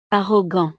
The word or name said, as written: arrogant